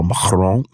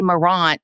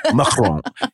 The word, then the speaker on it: Marant: I've worked for Isabel Marant. – A lot of people say. Nice pronunciation. – Mm-hmm, you know. – We say Marant in the South. – Mm-mm, it's Marant. You got to spit it up.